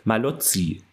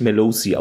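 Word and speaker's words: Mallozzi: Ach so, was ich fast noch vergessen habe, unser guter Freund, ich hoffe, ich spreche es jetzt auch mal richtig aus, Joseph Malozzi, den spricht man nicht Melozzi aus, das habe ich immer falsch gemacht,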